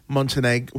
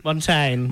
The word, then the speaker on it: Montaigne: I was in the blue Montenegro... How do I say it? – Montaigne.